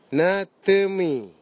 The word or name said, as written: natimi